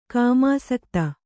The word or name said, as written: ಕಾಮಾಸಕ್ತೆ